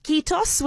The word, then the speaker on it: kiitos: and I hope I'm going to pronounce it correctly in saying kiitos, which is the word for thank you. Is that correct, Heli? – That's very, very correct.